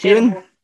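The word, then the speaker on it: Heughan: I can't even pronounce his surname, and I'm Scottish, and that's Hewen. Hewen. That's it.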